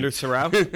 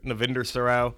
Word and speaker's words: Navinder Sarao: Navinder Sarao. UK, he's a day trader, Navinder Sarao.